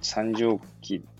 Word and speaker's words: 三畳紀: 三畳記っていいんだよな、読み方が。三畳記ですよね、これね。これね、とかっつって。三畳記でいいんだよね、読み方が。いつもね、読み方がわかんないんですよね。ちなみに三畳期、あ、合ってます。